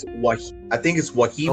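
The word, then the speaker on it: Wahiawa: I'm going to call it the Wahawa. I want to say that's how it's pronounced. The Wahawa. – I think it's Wahiwa.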